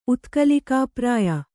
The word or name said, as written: ಉತ್ಕಲಿಕಾಪ್ರಾಯ